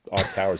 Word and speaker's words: iCAUR: iCar, which is spelled I-C-A-U-R, but that's iCar is how they were pronouncing it.